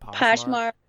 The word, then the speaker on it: Poshmark: Have you seen those apps like Poshmark? Is that how you say it? – Poshmark. – Or Depop? Yeah, Poshmark.